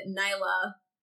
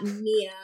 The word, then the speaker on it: Niylah: even though they pronounce it Niylah and not Nia, like the way I pronounce Mila.